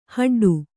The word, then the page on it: ಹಡ್ಡು: haḍḍu